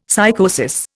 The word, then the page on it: psychosis: Indian